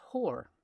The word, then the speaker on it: hour: Now, in Shakespeare's day, hour would have been pronounced whore.